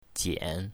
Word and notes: jian3: mandarin

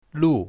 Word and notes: lu4: mandarin

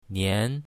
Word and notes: nian2: mandarin

